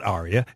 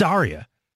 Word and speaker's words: Arya: It's not Arya. It's Arya. It's like, what?